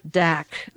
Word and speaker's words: Dacre: Now, there was an article in the London Observer, The Tragic History of Double Dack. The R-E is silent. D-A-C-R-E.